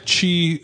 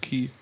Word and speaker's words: Chi: It looks like Chi Spaka, but it's pronounced Key.